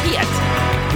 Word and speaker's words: Peart: Oh, it is actually pronounced Peart. – So I'm learning something. – Right. Well, Americans, you know, we botch. – Peart.